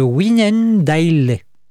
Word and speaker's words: Wynendaele: Et le 25 mai, le roi et ses ministres se réunissent au château de, alors attention, de Wynendaele. Donc, je crois que ça se prononce comme ça. Je vous l'appelle W-Y-N-E-N-D-A-E-L-E.